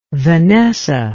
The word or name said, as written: vanessa